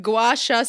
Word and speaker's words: Gua Sha: Gua Sha Stone.